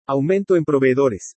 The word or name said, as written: AUMENTO EN PROVEEDORES